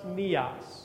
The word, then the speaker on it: neos: And that Greek word that means that, the way we understand new, it's pronounced neos.